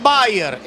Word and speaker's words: Beier: Este se pronuncia igual, pero se escribe distinto al de la aspirina. Maximilian Bayer. Este es B-E-I-E-R.